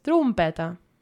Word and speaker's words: trompeta: Again, it's spelt with an O, yeah, but it's trompeta.